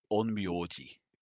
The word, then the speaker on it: Onmyoji: So he's got kind of like this long coat that's sort of buckled up, but the Onimoto Null and the Onimoto Onmyoto, I think I'm pronouncing it wrong. – We are going to be really particular on your pronunciation. – Yeah. I'm sick, so that's my excuse. – Okay, just to fuck with Max. Onmyoto Onmyoji.